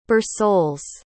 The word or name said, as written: Bersules